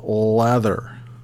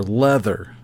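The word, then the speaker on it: Läther: But we're going to talk about the album Lather, which can either be pronounced as lather, as in soap, or leather, as in the material.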